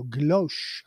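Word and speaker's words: guilloché: Guilloche. I love guilloche. It's spelled G-U-I-L-L-O-C-H-E with a tilde over the E.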